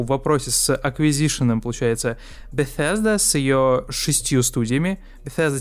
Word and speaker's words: Bethesda: Bethesda. – Bethesda, да.